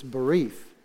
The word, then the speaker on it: berith: A covenant is in the Hebrew, is berith. And honestly, it's B-E-R-I-T-H, if you were to do a transliteration on it. But the spelling, how you pronounce it is B-E-R-E-E-T-H. So it's berith.